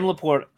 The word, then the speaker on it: LaPorta: Is Laporta? Is that how you say it for Detroit? The rookie tight end. I'm picking him up as much as possible late in drafts. – Sam Laporta.